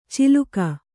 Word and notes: ಚಿಲುಕ: ciluka

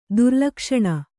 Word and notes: ದುರ್ಲಕ್ಷಣ: durlakṣaṇa

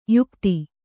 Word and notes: ಯುಕುತಿ: kannada

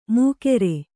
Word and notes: ಮೂಕೆರೆ: Kannada

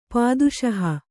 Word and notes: ಪಾದುಶಾಹ: kannada

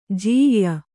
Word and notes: ಜೀಯ್ಯ: jīyya

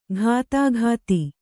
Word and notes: ಘಾತಾಘಾತಿ: ghātāghāti

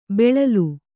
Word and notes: ಬೆಳಲು: beḷalu

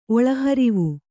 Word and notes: ಒಳಹರಿವು: oḷaharivu

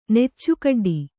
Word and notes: ನೆಚ್ಚುಕಡ್ಡಿ: Kannada

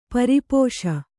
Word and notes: ಪರಿಪೋಷ: pari pōṣa

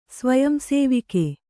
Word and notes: ಸ್ವಯಂಸೇವಿಕೆ: svayam sēvike

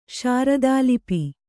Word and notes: ಶಾರದಾಲಿಪಿ: kannada

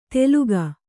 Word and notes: ತೆಲುಗ: teluga

